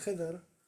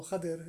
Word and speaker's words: Khidr: And this hadith is on the story of Musa and Khidr, or Khadir, it's pronounced in different ways.